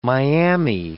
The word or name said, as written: Miami